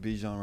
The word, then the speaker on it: Bijan: Bijan Robinson.